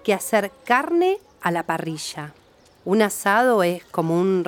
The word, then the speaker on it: parrilla: In Argentina, often the double L's and Y's are pronounced with a J sound, as in parrilla.